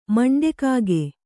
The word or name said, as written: ಮಂಡೆಕಾಗೆ